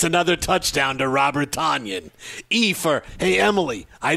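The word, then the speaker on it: Tonyan: His name is Tanyan. Robert Tanyan. – Robert Tanyan. Say it like Robert Loggia.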